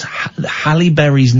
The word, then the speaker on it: Halle Berry: The final, and we have Holly Berry? – Holly Berry? As in the berry... the berry for Christmas? Holly Berry. I've never heard, and the fact that you mispronounced Halle Berry's name so